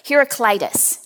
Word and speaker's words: Heraclitus: As I was studying, I came across a quote by the Greek philosopher Heraclitus. Now, yes, I did have to look up the pronunciation of that name, and that's how they told me it was pronounced.